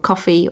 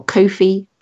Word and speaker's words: Ko-fi: We had a couple of people saying, how do you spell Ko-fi, in the past, so I thought I'd best just the pronunciation that always that always baffles me is where you keep going for Ko-fi. I can understand Ko-fi as in lo-fi or coffee as in the drink, but Ko-fi as in Anan is the one that baffles me.